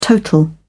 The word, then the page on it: total: British English